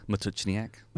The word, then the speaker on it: Matuchniak: Matuchniak. It sounds so easy when you say it. I have to work on that. – It's just four syllables. – That's true. That is a good way to think about it, four syllables.